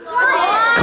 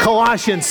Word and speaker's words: Colossians: Colossians. – Colossians.